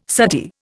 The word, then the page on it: suttee: Indian